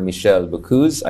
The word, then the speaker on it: Michéle Boccoz: We are honored to be joined by the French Ambassador to the Philippines, Your Honor, Ambassador Michéle Boccoz.